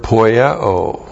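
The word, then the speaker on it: Poieo (ποιέω): And then the verb poieo. P-O-I-E long O. Poieo. One.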